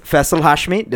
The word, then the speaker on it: Faisal Hashmi: His name is Faisal Hashmi.